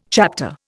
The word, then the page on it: chapter: Indian